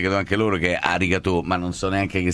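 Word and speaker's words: arigato (ありがとう): La pronuncia, diciamo, base giapponese sarebbe arigato. – Ma un suono è maschera, è arigato. – Arigato. – Arigato. – La G giapponese è un po' più gutturale della nostra. Arigato.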